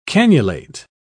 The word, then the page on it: canulate: British English